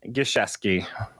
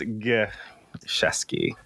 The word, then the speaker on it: Gieseski: Gieseski. – Oh, so it's a hard G even. – Yeah, it's not like a GIF. It's a GIF. So it's Gieseski. Gieseski.